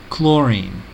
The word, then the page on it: chlorine: American English